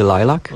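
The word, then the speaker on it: Lilac: Zespół Lilak, dobrze mówię? – To się wymawia tak naprawdę Lilak.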